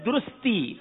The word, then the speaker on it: درستی: درستگی. – جی درستگی. – آپ نے کہا درستگی، حالانکہ یہ درستی ہے، یہاں غلطی ہو گئی ہے جناب. صحیح لفظ ہے درستی.